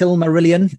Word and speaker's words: Silmarillion: How's it pronounced? Silmarillion? Is that the right way to pronounce it, Holly? I don't know if I'm doing justice to it there. – Yes, you are.